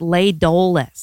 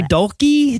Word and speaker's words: Dolce: I wondered how many, the place is called La Dolce, and I wondered how many people in this movie pronounced it Le Doles. Oh, yeah. It sounds Italian. Le Doles. Let's go. Le Doles. – Le Dolce? Dolce.